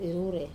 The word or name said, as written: rure